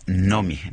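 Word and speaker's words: Noomi: En sueco se pronuncia Nomi, Nomi Rapaz.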